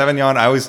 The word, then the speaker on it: Sauvignon: Sauvignon. – Cabernet Sauvignon.